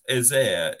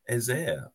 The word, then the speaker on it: Eze: Real Panel says Ezair is how he pronounced it in the player names video. Ezair.